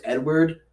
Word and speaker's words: Eadweard: His name's Edward, but it's spelled E-A-D. W-E-A-R-D.